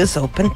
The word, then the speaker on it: US Open: Y el US Open, tal cual, muy bien pronunciado.